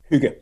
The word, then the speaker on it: hygge: Hygge. – Okay, hygge.